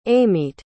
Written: amite

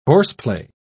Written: horseplay

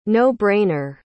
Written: no-brainer

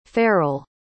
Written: farrell

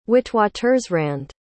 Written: witwatersrand

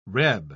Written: reb

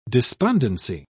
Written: despondency

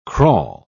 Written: crawl

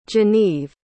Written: geneve

